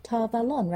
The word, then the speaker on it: Tar Valon: Tar Valon, right? Like Avalon.